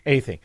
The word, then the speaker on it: Athey: Did I butcher your last name? – You were close. It's Athey. – Athey.